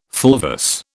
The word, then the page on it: fulvous: British English